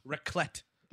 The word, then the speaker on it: Raclette: Raclette.